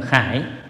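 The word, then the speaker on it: khải (楷): Chữ này có âm hai âm đọc, một âm là giai, một âm là khải. Nhưng ở đây chúng ta đọc là khải.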